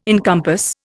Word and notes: encompass: Indian

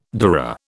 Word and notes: dura: British English

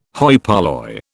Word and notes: hoi polloi: British English

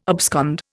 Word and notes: abscond: Indian

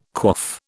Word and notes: quaff: British English

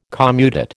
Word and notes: commutate: American English

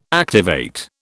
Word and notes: activate: British English

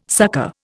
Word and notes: succor: Indian

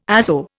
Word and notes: azo: Indian

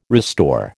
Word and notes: restore: American English

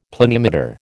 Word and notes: planimeter: American English

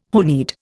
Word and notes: honied: Indian

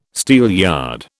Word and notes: steelyard: British English

